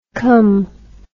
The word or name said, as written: com